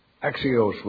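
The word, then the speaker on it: Axios (ἀξίως): Axios with the long O.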